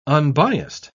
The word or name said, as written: unbiased